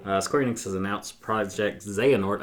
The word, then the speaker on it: Xehanort: I think they pronounce the X as in Latin for Z's again. So. – Xehanort?